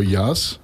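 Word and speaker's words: Yas: You just presented your latest release with the band called Trio Yaz. – Yes. – Is that the way you would pronounce it? Yes. – That's how we pronounce it. – I don't know. It's Y-A-S, so it could be.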